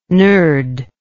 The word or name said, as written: nurd